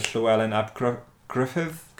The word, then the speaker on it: Llywelyn ap Gruffydd: So, and that was despite the efforts of Llywelyn ap Urwyth and Llywelyn ap Gruffydd.